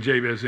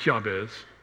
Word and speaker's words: Jabez: And those two, in fact, I'm saying Jabez incorrectly because I'm using East Tennessee English, making the J sound like a J. It's actually pronounced Jabez, but his name sounds like pain.